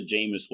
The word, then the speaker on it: Jameis: If you guys watch football, he's kind of like the Jameis Winston of disc golf. Yeah, I said that right. Yep. The first time, right?